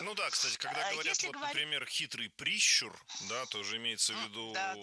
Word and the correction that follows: прищур: Не прищур, а прищур. Ударение в этом слове ставим на второй слог. Да там его и оставляем во всех формах. Только прищур, прищура, прищуре.